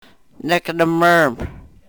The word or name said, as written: nek nr-mrm